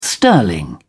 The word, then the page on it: sterling: British English